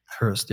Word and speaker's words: Hirst: Cause or John Curran or Damien Hirst. Did I say his name right? – Hirst, yeah.